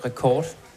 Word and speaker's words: Records: Vi udtalte det rekord. – Okay. – Men det er godt at få sagt det en gang for alle. – En fordanskning af record.